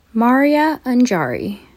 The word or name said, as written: Maria Anjari